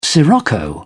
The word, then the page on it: sirocco: British English